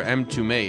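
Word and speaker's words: Mtume: This song, of course, by M2Me, which looks like M2M, if you don't know any better. M2Me is how it's pronounced.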